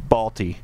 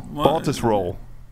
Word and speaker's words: Baltusrol: I'm I'm going to call it Balti because I, I you, corrected me last week with Baltusrol. – Yeah, you're struggling a little with the pronunciation. I, I yeah, roll, Baltus roll.